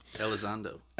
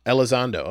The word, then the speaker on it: Elizondo: Luis Elizondo, I think that's how you say it. – Elizondo. – Elizondo, okay.